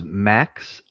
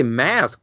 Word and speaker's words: Maks: I believe it's Max, M-A-K-S. – See, it flips it around because the name looks like mask, and I always want to say mask, but it's Max? – Yeah, I think so.